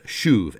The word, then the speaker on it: shuv: In Hebrew, the word is shuv, S-H-U-V. I always like to think of it as shove.